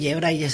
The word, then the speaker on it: Braille: Braille se dice como se lee. Vamos, como se escribe, se lee. – Bueno, es una palabra francesa, pero la hemos españolizado.